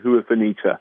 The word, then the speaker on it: Huerfanita: I'll spell it M-E-S-A, new word, Huafenita, H-U-E-R-F-A-N-I-T-A.